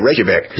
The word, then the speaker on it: Reykjavik: I know it's Iceland. I just wasn't sure how to say it. Reykjavik. – Yes.